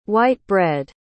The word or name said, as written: white-bread